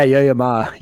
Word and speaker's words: Yo Yo Ma: What are Yo Yo Ma videos? Did I say that right? – Yeah. Yeah, Yo Yo Ma.